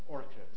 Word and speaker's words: Orkut: Or think about Orkut, or no, actually no, it's pronounced Orkut.